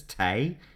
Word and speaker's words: tea: T-E-H, or directly T-E from the Min Chinese. The tea thing actually is funny because in English it was first pronounced as tay, T-A-Y, tay, you want a cup of tea? And it's sometimes still pronounced that way in existing dialects in English.